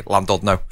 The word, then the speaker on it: Llandudno: Did you say it properly? – Yeah. Llandudno? – Oh my! – Isn't that right? – God. Yeah, but English people always just say Llandudno, and it always offends everybody. It's Welsh because we just obliterate the language, don't we?